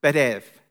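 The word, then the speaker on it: betev (בית אב): In Hebrew, father's house means, or it's pronounced betev.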